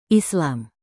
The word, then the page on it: islam: indonesian